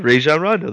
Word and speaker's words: Rajon Rondo: Did I say that right? – You said Rajon Rondo. That was very good.